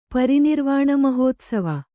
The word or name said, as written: ಪರಿನಿರ್ವಾಣಮಹೋತ್ಸವ